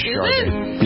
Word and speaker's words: Sade: There's a little bit of an R in there. – Sade? – Yeah, like Sade.